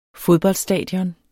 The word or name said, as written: fodboldstadion